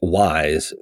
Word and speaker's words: Weiss: It's Wise, like Wise Old Owl, which is not advertising my wisdom, but that's the easiest way for people to remember how to say it.